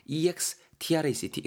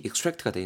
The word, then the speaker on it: extract: 요놈아 여러분, 지금 extract로 제가 발음했을 때는 동사로서 활용이 된 건데요. 무언가를 추출하다, 발췌하다라는 느낌 전하실 수가 있고요. 요놈아가 extract, 이렇게 extract가 아니고 extract, 이렇게 발음이 되는 경우 있습니다. 같은 단어인데 그때는 추출, 추출물이란 뜻으로도 활용이 될 수 있거든요. 요거 구분해서 챙겨야 됩니다.